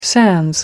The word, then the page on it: sans: American English